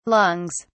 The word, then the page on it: lungs: British English